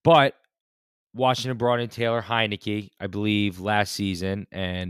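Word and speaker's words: Heinicke: And Heineke. – Taylor Heineke. – I knew you were. You can't pronounce that. – Heineke.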